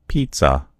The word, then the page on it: pizza: American English